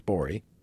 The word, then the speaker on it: Gborie: You can follow him at the G is silent. His last name is G-B-O-R-I-E, pronounced Borey. Get it?